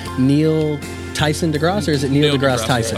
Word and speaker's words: Neil deGrasse Tyson: Neil deGrasse Tyson? – Tyson, yeah.